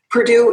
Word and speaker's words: Perdue: And Perdue, by the way, uh, is not spelled like the university. Perdue is chickens, and that's P E R D U E.